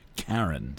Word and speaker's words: Charon: I think it's actually pronounced Charon, C-H-A-R-O-N. I prefer Charon, not to be confused with Chiron.